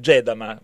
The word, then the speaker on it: Gedama: Benissimo, allora ho sbagliato l'accento, Gedama, va bene.